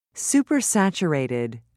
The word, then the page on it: supersaturated: British English